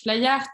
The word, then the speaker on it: Flyheart: Flyart, ça s'écrit F-L-Y-H-E-A-R-T, comme le cœur en anglais.